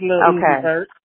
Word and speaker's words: Lil Uzi Vert: Yeah, it's Lil Uzi Vert.